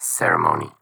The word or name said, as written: Ceremony